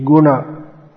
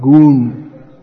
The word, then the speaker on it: guna: But actually, it's not a long U, but because of dropping the A at the end, it becomes pronounced as if it's along these two words. So, these words should be Guna, Arjuna, not Goon.